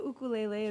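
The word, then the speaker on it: ukulele (ʻukulele): Yes, the ukulele is how it's correctly pronounced, but I also like to say it's correctly spelled with an okina before the U, which is an apostrophe. So usually I say ukulele.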